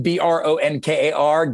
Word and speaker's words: Bronkar: And when I was, and trust me, I get many nicknames because that name, Bronkar, gets misspelled on a daily basis. – I'm sure. – It gets butchered in all kinds of ways. And it's like Bonker or Brancor or something, you know.